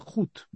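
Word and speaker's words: Hood: но Робин Гуд его называют только по-русски, на самом деле на английском он пишется не Гуд, он пишется Худ, а Худ это капюшон, то есть Робин закрыт капюшоном.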